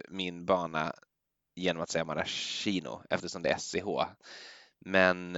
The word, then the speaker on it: maraschino: Ja, men då har vi väl en gång för alla rätt ut det, att det heter maraschino.